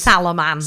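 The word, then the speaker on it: Salomon: Rick Salaman, is that how he pronounces? – Salaman. – Salaman, short for salamander, presumably.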